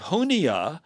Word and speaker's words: Junia: And by the way, the way that name is spelled with a J Yes. J U N I A. It's not starts with a an H, which it sounds like.